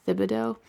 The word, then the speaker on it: Thibodeaux: And as I was flipping through this book, Reimagining the Ignatian Examen, by Mark Thibodeau, I think it's pronounced Thibodeau, T-H-I-B-O-D-E-A-U-X, Father Mark Thibodeau.